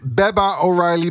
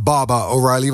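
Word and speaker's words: Baba O'Riley: Dit is mijn Engelse uitspraak, Peter. Het is niet Baba O'Reilly, maar Baba O'Reilly, want het zijn Engelsen.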